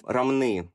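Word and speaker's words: Ромны: Дальше они переместились в город Рамны, ударение на последний слог.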